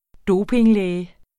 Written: dopinglæge

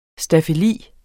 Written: staffeli

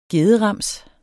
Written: gederams

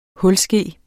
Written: hulske

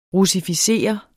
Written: russificere

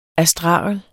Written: astragel

